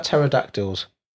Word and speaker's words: pterodactyls: What's that thing about pterodactyls? – They're not dinosaurs? – No, some joke about... Because the P is silent.